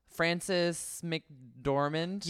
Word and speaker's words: Frances McDormand: So essentially it's about Frances McDormand.